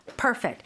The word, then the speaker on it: perfect: So the accent is on the first syllable. Perfect. Here, it's a verb, so it follows the second syllable.